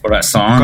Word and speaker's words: Corazon: And that was FM Attack with the track, am I going to say it right? Corazon. – Corazon. – Corazon. Corazon. – Perfect.